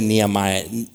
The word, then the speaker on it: Nehemías: Y si voltamos a Nehemías, ¿cómo se dice Nehemías? Nehemías, Nehemías.